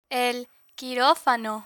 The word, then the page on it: el quirofano: Spanish